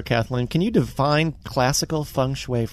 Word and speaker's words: Feng Shui: Feng Shui.